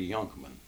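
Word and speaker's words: Jonkman: Yonkman, started with a J. When you pronounced it Yonkman, J, it was spelled like Junkman.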